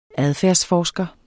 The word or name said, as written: adfærdsforsker